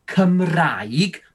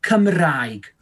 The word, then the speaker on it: Cymraeg: Well, so as you can see, the word Cymraeg, officially we say it like Cymraeg. But because we're in Welsh West Wales, we speak a bit of dialect. So for those two letters there, we'd say ah. So we'd say Cymraeg.